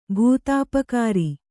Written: ಭೂತಾಪಕಾರಿ

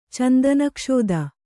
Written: ಚಂದನಕ್ಷೋದ

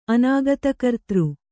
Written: ಅನಾಗತಕರ್ತೃ

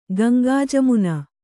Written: ಗಂಗಾಜಮುನ

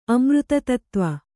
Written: ಅಮೃತತತ್ತ್ವ

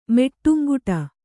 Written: ಮೆಟ್ಟುಂಗುಟ

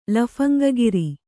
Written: ಲಫಂಗಗಿರಿ

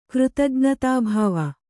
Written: ಕೃತಜ್ಞತಾಭಾವ

